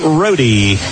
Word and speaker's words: Rohde: Owen Rohde steps up there, spells his name R-O-D-E, and he pronounces it Rohde, and it's up and good.